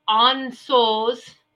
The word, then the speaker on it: Ansuz: And this one is, it's spelled A-N-S-U-Z, but it's pronounced Ansuz. Ansos.